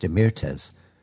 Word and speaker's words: Demirtas: I think his name is pronounced Demirtas.